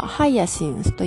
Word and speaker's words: hyacinth: ヒヤシンスは英語ではハイヤシンスと言います。ハイヤシンスは、ハイヤシン、スと最後のスは舌を噛む発音 TH です。